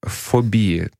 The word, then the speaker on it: фобии: И а, Фобии, да, действительно, так правильно ставить ударение, но так э, правильно с медицинской точки зрения. То есть врачи делают ударение на последнем слоге и на предпоследнем.